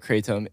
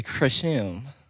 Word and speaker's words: kratom: Anyway, today in this episode of Drinking Out of Cups podcast, we're talking about Kratom. – Yeah. – As Hamilton Morris would say, it's Kratom. – Actually, you're wrong. Despite the most people calling it Kratom, it is actually Kratom.